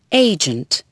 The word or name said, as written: agent